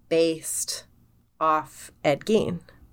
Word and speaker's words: Gein: It's pronounced Gein and not Gein. A lot of people pronounce it Gein. – And they'd be wrong. – They'd be wrong. And you know how you can remember? Gein rhymes with fiend. Rhymes with peen.